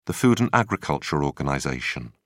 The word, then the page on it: food and agriculture organization: British English